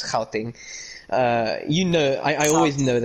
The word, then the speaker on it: Gauteng: Gauteng. – Sorry, yeah, I mean, you're a bit overpronouncing it, but that's good. You're actually doing, yeah. Helping. Yeah. Yeah, you did it right there.